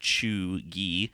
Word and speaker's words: cheugy: According to the New York Times, cheugy, pronounced cheugy, can be used broadly to describe someone who is out of date or trying too hard.